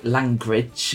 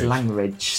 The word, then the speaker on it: Langridge: Do you say Langridge, or is it more of a hard G than that? – So I say langridge. – Yeah, my dad says langridge.